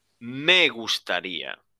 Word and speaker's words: me gustaría: So, that me gusta is transformed into me gustaría. Me gustaría. Can you repeat with me? Me gustaría. – Me gustaría. – Perfecto. Very good pronunciation.